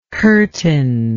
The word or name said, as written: curtin